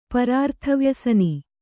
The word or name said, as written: ಪರಾರ್ಥವ್ಯಸನಿ